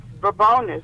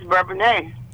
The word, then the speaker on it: Bourbonnais: Bourbonnia, Illinois. – Hi. – Is it Bourbon? Hi. How do you say the name of your city? – Well, if you're from the city, it's Bourbonnais, but if you move into the little village, it's Bourbonnais.